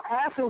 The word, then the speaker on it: Asaph: So Asa was one of the leaders of musicians in the Tabernacle of David. David appointed Asa. His name is spelled A-S-A-P-H, in case you're not understanding how I'm pronouncing it.